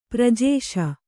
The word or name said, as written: ಪ್ರಜೇಶ